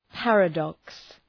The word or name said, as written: paradox